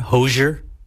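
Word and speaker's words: Hozier: I pronounce it kind of like with a very soft SH sound. Hosier.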